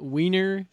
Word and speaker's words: Wiener: In German, if you were speaking German, it would be like a V. Wiener Schnitzel.